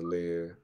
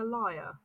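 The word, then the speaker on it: lyre: Would you pronounce that a leer? – Is it what? Is it a liar? I don't know. – I consider it to be a liar. And every piece of media that I've heard it allowed... calls it a liar. – Right, okay, fine. It's probably a liar then.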